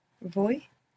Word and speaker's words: voy: Voy. – The beginning bit of voyage. And you're mostly going to hear it pronounced more like a soft B, boy, boy.